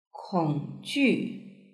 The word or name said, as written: kongju